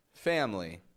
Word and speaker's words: family: But for today, notice in the word family, I'm not pronouncing the I. I'm not saying family or family, but rather family. Family. Family. Americans have totally dropped the I in pronunciation from that word simply because it's such a common word, and we pronounce it so fast, family, family, that there's no time for the schwa. There's no time to say family.